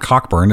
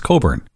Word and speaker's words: Cockburn: Is it a silent S-C-H-I-T-S? it's like the name Coburn in Australia. It's a silent C-K. – Yeah, it's not Cockburn, it's Coburn. – Yeah, sure. Just ignore the C-K.